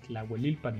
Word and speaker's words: Tlahuelilpan: en la Plaza de Toros Jorge Gutiérrez de Tlahuelilpan, Hidalgo.